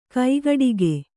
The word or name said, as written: ಕೈಗಡಿಕ